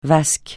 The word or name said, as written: vasque